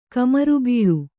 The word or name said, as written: ಕಮರುಬೀಳು